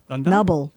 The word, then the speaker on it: Nubble: Nubble. N-U-B-B-L-E. It's the Nubble Lighthouse. – Now, doesn't Nubble sound like what you call a guy that's lost his male member in the war? – That poor Nubble.